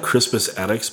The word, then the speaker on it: Crispus Attucks: Yeah, you remember that Crispus Attucks band? – Yeah.